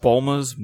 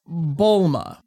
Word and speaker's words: Bulma: Yeah, it would be something like Bluma.